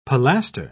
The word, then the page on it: pilaster: British English